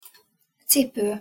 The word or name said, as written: cipo